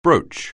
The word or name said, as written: brooch